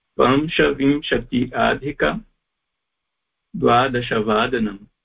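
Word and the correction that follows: पञ्चविंशति: Pancha vimshati.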